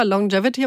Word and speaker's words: Longevity: Longevity habe ich richtig ausgesprochen? – Korrekt, sagt Frau Dr. Gartenbach.